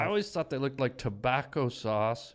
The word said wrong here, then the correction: Tabasco: How do you say it? – Tabasco? – Tabasco sauce mixed with beef gravy.